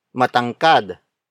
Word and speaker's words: matangkad: The next example is matangkad. Matangkad. Matangkad. Matangkad is spelled as M-A-T-A-N-G-K-A-D.